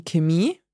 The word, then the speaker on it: Chemie: There are some words where you pronounce it as a K. Mm-hmm. So, for example, der Chor. – Der Chor, the choir. – The choir, yes. Or we have die Chemie. – Die Chemie, like chemistry. – Yeah. – Would I have heard that pronounced die Chemie? – Yes, probably. Yeah. So here, this one really varies on where you are. Mm. So it really varies on the region and dialect you're hearing in German.